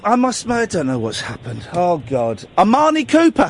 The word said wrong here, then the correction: Imani Coppola: has, has she? Yeah. Who? Your friend from New York. Oh, Imani Coppola. Coppola.